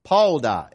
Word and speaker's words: padah: One of those words is the word Pauda, and it's just simply spelled like this, P-A-D-A-H.